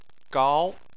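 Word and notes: gao: tone 2

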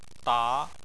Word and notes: da: 2

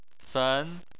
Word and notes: fen: 2

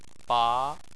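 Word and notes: ba: tone 2

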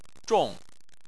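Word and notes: zhong: tone 4

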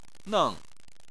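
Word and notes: neng: tone 4

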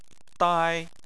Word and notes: dai: 1

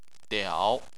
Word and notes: diao: 3

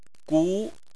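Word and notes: gu: tone 2

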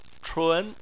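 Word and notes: chun: tone 2

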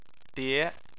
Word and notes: die: 2

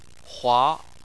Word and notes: hua: 2

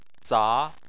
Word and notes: za: 2

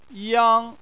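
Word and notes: yang: tone 1

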